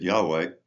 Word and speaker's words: Yahweh: And then when a mistake, when they came from German to English, they didn't know that a J in German is pronounced like a Y. And so they pronounced it now Jehovah. But Jehovah is really just a transliteration, an error, going from Yahweh to Jehovah.